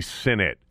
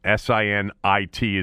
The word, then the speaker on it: Sinnott: I thought it was Sinnott, but I went and looked at the – you know, how it was pronounced, and it's actually Sinnott. Sinnit is the way it's spelled out phonetically.